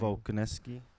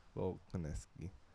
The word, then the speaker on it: Volkanovski: Volkanovski. Volkanovski.